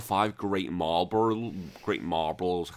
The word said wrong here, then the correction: Malboros: Malboros, yeah.